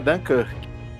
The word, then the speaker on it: Dunkirk: A pronúncia do, do título é Dunkirk mas ah, acho, se eu não me engano, em português é Dunkerque.